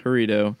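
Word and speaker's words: Jarritos: I do want to know what, like, specifically made Faygo better than Aritos. As I'm going to stop saying the J. – Arito. – Aritos. – I really like the... So, Arito, or how it should be pronounced, Harito, is...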